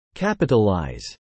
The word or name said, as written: capitalize